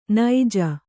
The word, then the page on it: ನಿಜ: nija